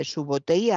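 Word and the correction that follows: Subotai: a Gengis Khan cuando se unió a él. Su Subotei le prometió esto,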